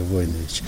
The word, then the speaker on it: Войнович: Есть какие-то семейные предания, и есть у меня книга, между прочим, из Сербии, наш род, Войнович, правильно, ударение Войнович.